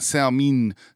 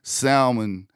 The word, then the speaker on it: salmon: Yes, salmon, not salmin, salmon, amon, salmon. That's how you pronounce it.